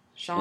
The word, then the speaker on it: Shox: Well, in this issue, he fights some guy with, like, vibratory powers, whose name is S-H-O-X. – Shocks. – And they even mention, yeah, it's pronounced like Shocks, only spelled with an X.